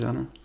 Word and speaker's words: genre: Is that how you pronounce it, genre? – Yeah.